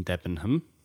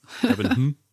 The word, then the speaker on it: Debenham: So for those of you that don't know, leads one of our locations in Debenham. That's not how you say it, is it? I don't know why I said it weird. – What, Debenham? – Yeah, Debenham.